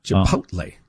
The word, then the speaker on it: Chipotle: So it's Chipotle. That's the emphasis is on pote. – Chipotle. – Chipotle.